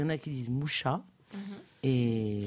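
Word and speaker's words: Mucha: Donc, je vais dire Moucha. – D'accord, on va dire Moucha. – Moucha. On va rester bien français et prononcer français.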